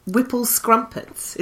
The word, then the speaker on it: Whipple Scrumpets: Yeah, I love the fact that Oompa Loompas were called Whipple Scrumpets.